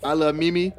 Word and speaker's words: I Lov3 Mimi: Aliche and I Love Mimi. That's how you say it. I Love Mimi.